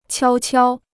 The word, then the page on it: 悄悄: qiāo qiāo